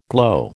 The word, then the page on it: glow: American English